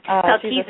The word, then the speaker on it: Melchizedek: Is a minister of Melchizedek, if I say that right. – Melchizedek.